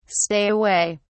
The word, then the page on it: stay away: British English